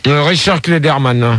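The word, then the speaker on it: Clayderman: Non, Richard Kleptoman. – Ah ouais. – Richard Klederman. – Ah oui, voilà, Richard Klederman, voilà.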